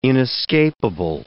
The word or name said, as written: inescapable